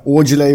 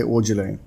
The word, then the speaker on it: Aujalay: Aujalay uttalas... Det är brittiskt uttal på ja. – namnet, så man säger Aujalay.